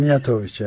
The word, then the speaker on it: Mijatović: Diğer bir husus, Demirtaş kararından ve Kavala davasına ilişkin Türkiye'deki gelişmeleri Doçevele'ye, Türkçe'ye değerlendiren Avrupa Konseyi İnsan Hakları Komiseri de Mijatović, yanlış okumadık, telaffuz etmiyorsam. – Mijatović, evet.